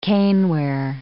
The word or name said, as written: caneware